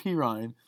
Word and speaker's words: Perine: It's like Perine.